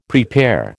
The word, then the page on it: prepare: American English